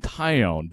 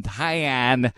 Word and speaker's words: Taillon: Jameson Taillon, who has been bad for the Cubs, and his name is pronounced Jameson Taillon. But 50% of the people say Taillon, and I just don't understand. I don't get it.